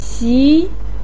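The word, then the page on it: xi: tone 2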